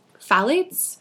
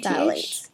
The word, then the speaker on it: phthalates: But the three, I think, that are getting the most buzz right now are phthalates, which is spelled with a PH. – It's PTH. – PTH? – Phthalates. Yeah. – Phthalates.